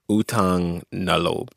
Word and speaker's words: utang na loob: Utang na loob.